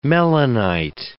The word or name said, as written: melanite